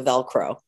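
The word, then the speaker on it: Velcrow: So that's, but it's spelled Crow, C-R-O-W, so Velcro.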